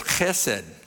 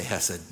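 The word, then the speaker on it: hesed (חסד): But if you say it correctly in Hebrew, you add a sound to it, like you're going to spit. So it's hesed, like that. But in English, you could just say hesed.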